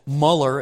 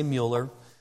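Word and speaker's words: Müller: In 1863, a gentleman named George Mueller, I've heard it pronounced Muller as well. I'm going to say Mueller.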